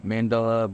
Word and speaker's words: mandala: The Mandela? – Yeah, it's a mandala bowl. – Mm-hmm. Wait, is that what you're saying? Not Nelson Mandela. – Different pronunciation. Yeah, different okay. Got it, emphasis.